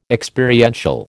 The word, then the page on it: Experiential: American English